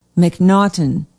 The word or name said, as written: mcnaughton